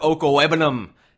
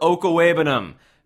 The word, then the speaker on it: Okwuegbunam: Mizzou guy, Albert Okwuegbunam. They can't say his name, but I can. Albert Okwuegbunam from the University of Missouri.